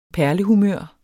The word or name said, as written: perlehumør